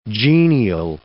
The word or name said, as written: genial